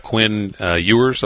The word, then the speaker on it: Quinn Ewers: ESPN and others have reported that this kid from Texas that enrolled early, a quarterback, Quinn Ewers, I believe is the pronunciation, mm-hmm.